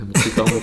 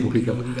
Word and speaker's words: Mujica: Sí, no, sé cómo se dice, Mujica. – sí, sí, sí, sí, Mujica.